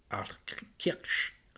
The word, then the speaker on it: Altkirch: de le faire vivre à Altkirch, donc également à prononcer à l'allemande,